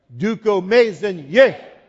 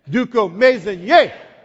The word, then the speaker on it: Dukomaezinyeh: Dukomaezinyeh. One more time, better than that. Dukomaezinyeh.